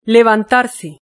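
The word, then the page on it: levantarse: Spanish (Spain)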